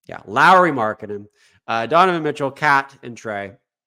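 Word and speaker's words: Lauri Markkanen: We said that wrong last podcast. Someone shouted it out. Lowry Markkanen.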